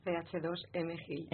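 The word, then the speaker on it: CH2M Hill: Y esta tarde también nos acompaña titulada, que actualmente está trabajando en la empresa CH2000 GIL, que es la ingeniería de ONO. No sé si lo he dicho bien. ¿Está bien dicho? – CH2M GIL. – M-GIL, bueno.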